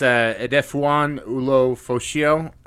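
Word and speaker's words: Edefuan Ulofoshio: Yeah, it's Edefuan Ulofosio. I probably butchered that as well. – It sounded like it was right. It sounded like it sounded good.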